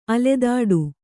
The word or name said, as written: ಅಲೆದಾಟ